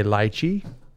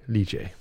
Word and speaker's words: lychee: Okay, so the British say lychee, and Americans typically say lychee.